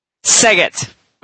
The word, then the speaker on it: Szeged: But so you pronounce it Sega? – Seget. – Oh, Seget. Okay. – Yeah. – It's spelled Szeged, and it is in Hungary.